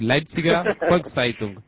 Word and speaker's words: Leipziger Volkszeitung: ¿Está bien pronunciado eso? – Más o menos, es Leipziger Volkszeitung.